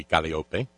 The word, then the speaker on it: Calliope: Because we all know it's Calliope. I guess the proper pronunciation in ancient Greek would be Calliope.